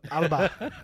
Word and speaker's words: Alba: Alba. Alba. – Alba. – Alba is Scotland. – Alba. – Alba really? Is Scotland. – A-L-B-A, pronounced A-L-B-A-H, Alba.